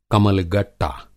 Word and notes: कमल-गट्टा: Hindi

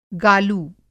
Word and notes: गालू: Hindi

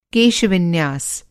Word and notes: केश-विन्यास: Hindi

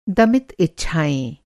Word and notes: दमित: Hindi

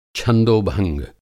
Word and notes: छंदोभंग: Hindi

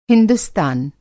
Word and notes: हिंदुस्तान: ہندوستان

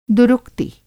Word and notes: दुरुक्ति: Hindi

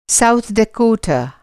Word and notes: साउथ डेकोटा: Hindi

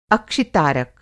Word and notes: अक्षि-तारक: Hindi

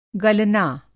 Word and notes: गलना: گلنا

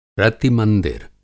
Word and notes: रति-मंदिर: Hindi

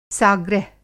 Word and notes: साग्रह: Hindi

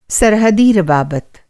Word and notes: राब्ता: رابطہ

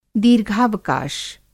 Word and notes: दीर्घावकाश: Hindi

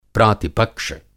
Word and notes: प्रातिपक्ष्य: Hindi

